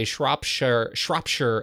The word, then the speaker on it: Shropshire: Here we say Shropshire, as in Shropshire, as in shirt, without the T.